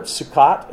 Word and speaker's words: Sukkot: This is the time of the Feast of Tabernacles, the Feast of Booths, or I'm going to butcher the word, Sukkot, is that what it's called? Just close enough.